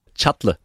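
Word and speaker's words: Çatlı: Nu har du fortalt mig, hvordan man udtaler det her navn. Chatle. – Chatle. Ja, Ab- Abdullah Chatle.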